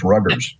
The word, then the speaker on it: Rutgers: And I'll add too, and especially for our listeners on the East Coast, he is a graduate of Rutgers University, which I know my South Jersey friends pronounce as Ruggers.